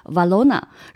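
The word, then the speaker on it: Valrhona: 我们当时西语会读作它 Valona，然后在英文会翻译成法芙娜，是法国产的。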